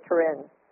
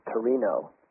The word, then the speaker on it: Torino: Or is it Turin? – Well, actually, in Italy, it's Torino. – Torino. How do you spell that? – T-O-R-I-N-O. – Okay. – But over here, we call it Turin, T-U-R-I-N, which is also the home of the Shroud of Turin.